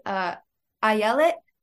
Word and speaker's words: Ayelet: First of all, my name is pronounced Ayelet, as in Ayelet. I yell it.